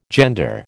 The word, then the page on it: gender: American English